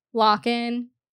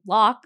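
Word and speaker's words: Lochan: I heard them say so it's Lock, so like a lock.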